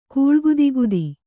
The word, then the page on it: ಕೂಳ್ಗುದಿಗುದಿ: Kannada